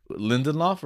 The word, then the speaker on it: Lindelof: Yeah, we'll right, so it's like Lindelof, right?